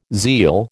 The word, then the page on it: zeal: American English